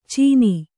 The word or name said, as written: ಚೀನಿ